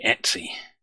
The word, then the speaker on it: /etc: So 1566 was Steve Bickle coming back to me after the last week's show saying how /etc is pronounced, because I'd said I always pronounce it etc, and he was pointing out that I was wrong and it should be Etsy.